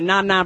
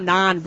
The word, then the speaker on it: naan: Yeah, I always mispronounce it. I think it's called naan bread. I always call it na-na bread.